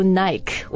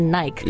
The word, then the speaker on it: Nike: Now, Spencer, 我以前以为这个读 Nike. 我很小的时候，因为按照英语的发音，我就想说 L-I-K-E 是 like, 那 N-I-K-E 就是 Nike. – Yeah. – 后来发现，哦, no, it's Nike.